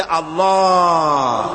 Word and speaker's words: আল্লাহ: আল্লাহ পাকের উচ্চারণটা হচ্ছে, আল্লাহ পাকের নামের উচ্চারণ হচ্ছে আল্লাহ। মুখটা একটু গোল হয়ে যাবে, বলেন আল্লাহ আল্লাহ।